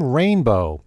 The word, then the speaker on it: Rainbo: Rainbow. Spell that R-A-I-N-B-O, like the word rainbow without the W.